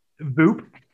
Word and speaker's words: Voop: Voop. V-O-O-P. Like Goop, but like V for Venture, right? – Oh, Voop.